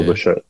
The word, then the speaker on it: Bichette: I do have a man crush on Bo Bichette. So. – Bo Bichette in Toronto. – Yeah, because his name kind of rhymes with Boba Fett, you know? – Was that Star Trek?